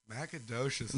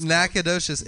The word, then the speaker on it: Nacogdoches: Nacogdoches, that's what it's Nacogdoches.